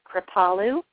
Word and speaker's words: Kripalu: And I just do want to say that the pronunciation is Kripalu yoga.